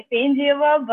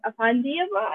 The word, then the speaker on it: Afandieva: Okay, so like a Russian version is like Efendieva, but a better way to pronounce it, Afandieva. I think that's how people pronounce it here.